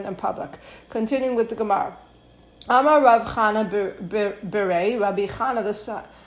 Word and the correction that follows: Chinana: Anam, Rav Cha, Rav, okay, I said it wrong. Amar Rav Chinana, Berei de Rav Idi.